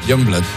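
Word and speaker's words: Yungblud: ¿Cómo se pronuncia? – Youngblood.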